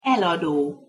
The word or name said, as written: elado